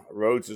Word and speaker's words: Rhodes: Rhodes is spelled R-H-O-D-E-S.